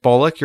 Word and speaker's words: Bullock: Okay, you're saying the word, like, the second half of, like, Bullock. You're saying lock. – Okay. – Instead of lick. Bullock. – But it's L-O-C-K.